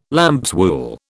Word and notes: lambswool: British English

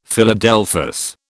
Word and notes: Philadelphus: British English

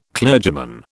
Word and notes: clergyman: British English

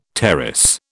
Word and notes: tarras: British English

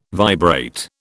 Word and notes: vibrate: British English

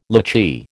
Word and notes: lychee: American English

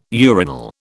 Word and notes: urinal: British English

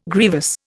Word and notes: grievous: Indian

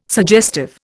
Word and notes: suggestive: Indian

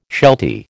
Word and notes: sheltie: American English

